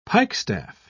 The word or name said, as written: pikestaff